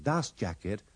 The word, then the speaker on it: dust jacket: Editorial Planeta de Agostini presenta Curso de Inglés Second Level Unit 34 Speaking Wading through Wogan In these sentences and dialogues, don't pronounce the T in dust jacket and dust cover, or the R in paperback.